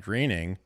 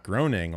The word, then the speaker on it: Groening: This is Matt Groening, which is how you pronounce his last name, not groaning like I've been doing for the first 32 years of my life, 33 years of my life.